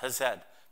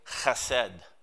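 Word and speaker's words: Chesed (חסד): It's spelled H-E-S-E-D. Chesed. It's pronounced, you'd think it's pronounced Chesed, but it's got a guttural sound. And it's pronounced this way, Chesed.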